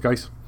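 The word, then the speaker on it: Guice: So that's Butch Geis, G-U-I-C-E. I think I'd never know how to pronounce that guy's last name. I'll go with Geis.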